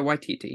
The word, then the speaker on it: Waititi: I'm going to say his name wrong. Taika Waititi? – Taika Waititi, yeah.